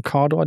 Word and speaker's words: Cawdor: In Cawdor, spricht man das so aus? In Cawdor, der Ort? – Cawdor, genau.